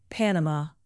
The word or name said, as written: panama